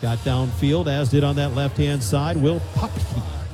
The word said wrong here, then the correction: Pepke: Actually, Pepke, the official pronunciation for the Rapid City native.